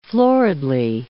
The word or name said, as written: floridly